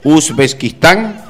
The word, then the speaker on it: Uzbekistán: Así se pronuncia, porque la primera una Z, Uzbekistán.